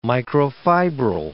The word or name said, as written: microfibril